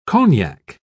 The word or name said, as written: cognac